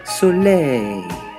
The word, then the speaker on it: Soleil: You're supposed to say it like the French people say it. Soleil.